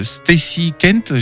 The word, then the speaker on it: Stacy Kent: Alors, un titre de Stacy Kent, j'espère que je prononce bien. – Oui, c'est ça, hein, oui.